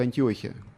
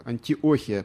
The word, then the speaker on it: Антиохия: Вот есть две Антиохии или Антиохии. Первая, если брать греческий подстрочник, сирийская, это Антиохия, почему-то там ударение стоит. А вторая, она где-то уже на территории Турции, Писидийская. Вот, и она называется Антиохия.